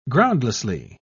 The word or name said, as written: groundlessly